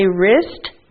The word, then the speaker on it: wrist: W is silent, so we say wrist.